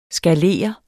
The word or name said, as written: skalere